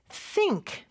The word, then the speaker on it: think: think 발음할 때는 요 th 발음 중요하죠. 쌍시옷 발음 아닙니다. th 발음을 할 때는 여기서는 무성음인데요. 윗니와 아랫니가 다 닫힌 상태가 아니라 살짝 뗀 상태에서 그 사이에 혀가 껴 있다가 뒤로 쏙 빠지면서 바람을 내뿜는 소리예요. 그래서 th, th 이런 소리예요. think, think, think이죠.